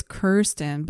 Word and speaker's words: Kirsten: Phonetically, it's Kirsten, but I'm a picky bitch.